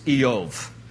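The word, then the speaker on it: Iyov: His name in Hebrew is pronounced Eov.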